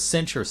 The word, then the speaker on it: censure: That's why I pronounce it, I have to emphasize the pronunciation there, because it's very close to censorship, which it isn't. Censure is something different.